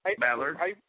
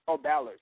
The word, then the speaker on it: Ballard: But, like last week they had Jake Billiard, however you pronounce his hit, Bollard? – Ballard. – Ballard, thank you.